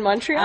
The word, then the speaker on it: Montreal: Just because it rhymes with the word small. – Just small, Montreal, yeah. – It rhymes with small, so we choose that city.